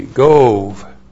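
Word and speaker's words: Gob: Number six is Gov. G long O B, Gove.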